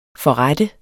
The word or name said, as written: forrette